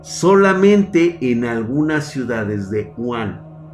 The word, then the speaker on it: Wuhan: Se pronuncia Wuhan.